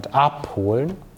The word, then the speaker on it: abholen: So it's about the stress. So with the, or for these separable verbs, you have the stress on the first syllable. So einladen, anfangen, and abholen, I'm exaggerating here to make it clear.